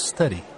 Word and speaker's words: study: W-E-E-K-D-A-Y-S. – Thanks. And how do you say ST in study? – The correct pronunciation is study. – There's no A at the beginning, right? – No, there isn't.